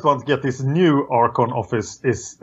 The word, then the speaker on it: Archon: The office of Archon. Is that how you say it in English? – Office of... – The Archon. – Yeah, Archon. That's how I would say it.